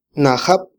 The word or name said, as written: najab